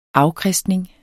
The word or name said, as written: afkristning